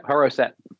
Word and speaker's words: Haroset: Haroset.